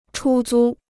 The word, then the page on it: 出租: chū zū